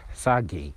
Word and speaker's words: Sergej: Like it's a big deal for them because he's a very talented guy, yeah, from Lazio, and he's Sergei. I think it's Sergei because it's Sergej when you just say it how it's spelled. It's Sergej, but I think it's Sergei Milinkovic Savic, guy from Lazio, midfielder, box to box, big.